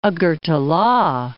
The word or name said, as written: Agartala